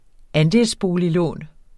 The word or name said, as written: andelsboliglån